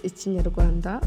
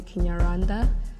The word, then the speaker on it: Ikinyarwanda: And we all speak the same language, which is Ikinyarwanda, or you can call it Kinyarwanda for ease of pronunciation, but it's better to call it Ikinyarwanda if you can.